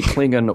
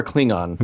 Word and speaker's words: Klingon: By the way, you know, if you can say Klingon or Klingon, then I say they're both perfectly acceptable pronunciations.